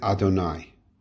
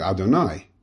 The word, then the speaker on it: Adonai: that we pronounce it Adonai, which is the master. That does not, this word, this name, Yud, the Hey and the Vav and the Hey, we say is that it's like the idea he was in the past, he's in the present, in the future. But we don't pronounce it, we say it Adonai.